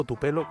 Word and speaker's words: Tupelo: Y, en realidad es un, eh, pasó muchas mucho, muchas, mucho tiempo de su vida cerca de, de Túpelo o Tupelo, como se pronuncia.